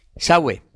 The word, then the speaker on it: Sawe: Lo ha hecho un keniano, Sebastián Sou, ¿se pronuncia? – Sawe. – Sawe. Sawe.